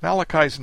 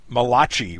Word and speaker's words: Malachi: I have a friend who calls him the Italian prophet by mispronouncing his name with the CH sound rather than the hard K. Malachi versus Malachi.